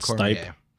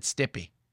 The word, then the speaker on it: Stipe: It's not Stipe. – He pronounced it Stippy.